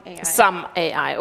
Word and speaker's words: SUM-AI: habe ich das richtig ausgesprochen? – SUM-AI, okay, ich dachte, das Doppel-M würde die deutsche Aussprache nahelegen.